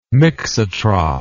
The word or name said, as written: mixotroph